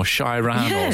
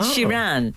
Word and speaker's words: Sheeran: Because remember, about 2 months ago, people were making fun of him because he did an interview and he kept saying Ed Sheeran or Shiran or something. – Ed Sheeran.